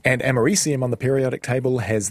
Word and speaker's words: americium: Americium, I think, is the correct pronunciation of that.